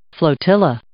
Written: flotilla

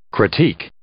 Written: critique

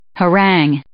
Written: harangue

